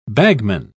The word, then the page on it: bagman: British English